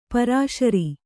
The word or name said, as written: ಪೇರುಸಿರು